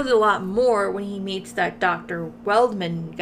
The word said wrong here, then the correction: Waldman: guy. Mm-hmm. I said it correctly this time, right? – Waldman. – Waldman? – Not okay. Weldman every time.